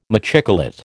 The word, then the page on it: machicolate: American English